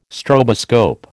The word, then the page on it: stroboscope: American English